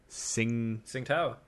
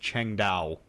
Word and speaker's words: Tsingtao: He apparently, a few shows ago, you said you like the Asian beer Tsingtao. Apparently, it's pronounced Chengdao.